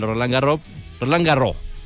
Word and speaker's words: Roland Garros: vamos a hablar del Roland Garros, es la pronunciación en francés, para los que no entendieron.